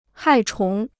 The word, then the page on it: 害虫: hài chóng